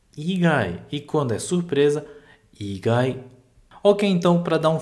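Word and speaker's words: igai (以外): Então, sugaku igai nara benkyou tetsudaemasu yo, e presta atenção nessa palavra, igai. Igai, agora é uma pronúncia de cima para baixo.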